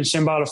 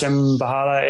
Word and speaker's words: Shambhala: You can find us on Facebook or Instagram under Shambhala Farm, and Shambhala Farm's got a silent H in there, so it's Shambhala, Shambhala Farm.